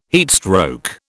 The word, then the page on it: heatstroke: British English